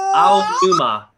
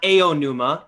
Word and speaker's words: Aonuma: or I don't know if it was Ah Aonuma. It's not Aonuma. It's Ah. It's Aonuma. Ken Watanabe. Every A is pronounced exactly the same, no matter where it is in the sentence.